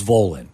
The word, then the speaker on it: Volen: I thought it was Volen. – Volen. – Rhymes with stolen.